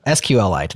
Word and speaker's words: SQLite: And I think for a long time, people sidelined MySQL, PostgreSQL, SQLite, or as it's actually called. Well, maybe I did say it right. SQLite. I don't know. I always say it wrong. SQLite. There you go. That's the correct way of saying it.